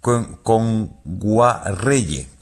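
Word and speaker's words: Kngwarreye: Nguagyerre, lo he dicho mal. – Emily Kame. – Con Guarreye. – Emily Kame con Guarreye, sí.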